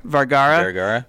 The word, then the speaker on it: Vergara: How do you pronounce Vergara? – Her? – Vergara.